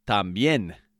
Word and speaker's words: también: Today's word of the day is también, which means also or too, as in T O O as well, right?